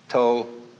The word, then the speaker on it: Tao: So their principle of nature is called the Tao, T-A-O, pronounced Tao in the Mandarin dialect, Tao in the Shanghai dialect, To in the Cantonese dialect.